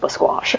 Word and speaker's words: Basquash: Seen some of the show. It's definitely pronounced Basquash.